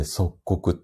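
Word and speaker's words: 率谷: えっ、ー、と、漢字で書くと、統率とかの卒、うんと、打率の率ですに谷で即刻って読みます。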